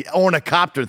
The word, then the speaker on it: Ornithopter: Ornithopter.